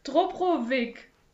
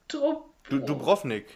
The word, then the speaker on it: Dubrovnik: Und zwar ist die Stadt Drup. Ich kann es gar nicht aussprechen. – Die Stadt Drupp. – Dubrovnik. – Dubrovnik.